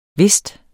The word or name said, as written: vist